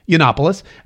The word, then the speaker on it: Yiannopoulos: I'll also teach you how to pronounce his name. It is Yiannopoulos.